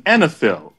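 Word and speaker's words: oenophile: AC was referred to as an enophil. Enophile, which is, before you get uncomfortable, it's a connoisseur. – I know, but I had to look it up. – Right. And it's pronounced enophil.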